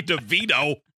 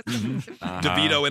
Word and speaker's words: DeVito: It was Danny DeVito. – Which rhymes with tuxedo. – DeVito in his tuxedo.